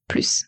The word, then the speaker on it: plus: On prononce toujours plus avec le S.